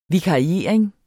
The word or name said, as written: vikariering